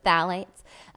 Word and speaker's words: phthalates: And we see things like plasticizers with BPA or, or, or, phthalates, they're called. I always mispronounce, but phthalates.